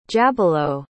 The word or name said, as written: jabilo